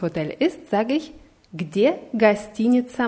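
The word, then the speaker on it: гостиница: Gastinica. Die Betonung ist auf I hinter T. Gastinica.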